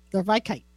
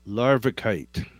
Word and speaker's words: Larvikite: Okay, it's spelled LARVIKITE. Larvikite. – Larvikite.